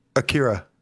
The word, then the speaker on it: Akira: I think it looks great because it has like this anime style, which obviously what they were going like Akira. – For. Mm-hmm. I'm sorry, how did you pronounce that? – Akira.